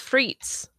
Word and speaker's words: frites: Do you pronounce it as frites? Frites? What? How do you say that? – It's frites. Steak frites.